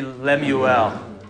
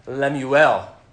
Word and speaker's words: Lemuel: One, two, three, Lemuel. Good. In Hebrew, you always accent the last syllable of a word. Lemuel.